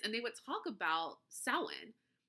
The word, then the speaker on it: Samhain: And I always thought Samhain was spelled like S-O-W-E-E-N.